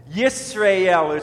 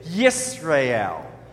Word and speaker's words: Yisrael: O Israel, Yisrael, it's three syllables to it, and it's spelled Y-I-S is the first syllable, then R-A for the second syllable, and then E-L. So you sort of say Yisrael. So try that, Yisrael.